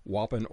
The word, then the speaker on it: Wappen: The book and the research that went into this, again, the title, Operation W-A-P-P-E-N, Wappen, is the English pronunciation. It's also a German word, I understand. Share with my listeners what that Operation Wappen or Wappen is about.